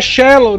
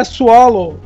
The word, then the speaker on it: shallow: Você não gritou assim, é shallow, não é swallow.